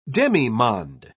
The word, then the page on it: demimonde: British English